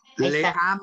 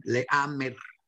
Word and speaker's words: Leamer: Lawrence Limen. – Limer. Mira. – Perfecto, ya, ya estoy. – Leamer. – Para los hispanoparlantes. Sí que luego se enojan cuando les corrijo la pronunciación, Leamer.